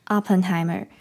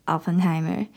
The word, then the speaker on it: Oppenheimer: 那我这边跟大家讲一下，嗯，那时候我讲 Oppenheimer，那发音的部分其实那个 O 应该是要在嘴巴张开一点，它有点像是 opera 的那个啊，所以应该是 Oppenheimer。那个 O 的部分就是要把嘴巴有点像是变成站起来的椭圆形那样子，发音会比较标准。那我当时实在是讲的太顺了，没有注意到。那我们可以一起练习一下 Oppenheimer，Oppenheimer。 Oppenheimer，对，那我之后呢会在发音的部分也多留意，也继续请大家多多指教了。